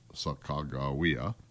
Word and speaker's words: Sacagawea: The story is that Sacagawea, pronounced Sacagawea, got deathly ill at the Great Falls and nearly died.